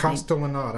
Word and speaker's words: Castellaneta: How do you pronounce his Castellaneta.